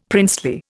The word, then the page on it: princely: Indian